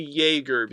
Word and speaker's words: Jaeger: Oh yeah, it's actually Jaeger, because it's yeah. one. It's like this show has like one of those weird things where it where the pronunciations are German. So like the J makes a y sound. It's the same thing with Erwin, because it's spelled E-R-W-I-N, but the W is pronounced as a V sound in German.